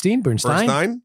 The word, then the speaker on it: Bernstein: Well, I mean, that question of whether this drives us to learn more about Leni Bernstein, I think is going to be an interesting one. – It's Bernstein, right? – Bernstein.